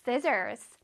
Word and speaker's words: scissors: scissors. scissors. Scissors 하면 가위라는 뜻이야. 그냥 시저가 아니에요. No, scissors, scissors. 자, 얼굴에 힘을 주고 scissors, scissors. 자, SEI 부분을 강렬하게 발음해주자.